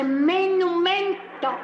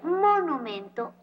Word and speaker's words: monumento: No se dice estuata, se dice menumento. – Pero qué mal están los dos. Ni se dice estuata, ni se dice menumento. Se dice monumento o estatua.